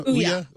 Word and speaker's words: Ouya: Ouya.